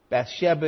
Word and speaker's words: Bathsheba: Bathsheba is the technical pronunciation. I'll probably flip back and forth between Bathsheba and Bathsheba, but in the Hebrew it's Bathsheba.